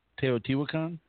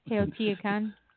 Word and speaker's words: Teotihuacan: Teotihuacan? – That's it. – Teotihuacan.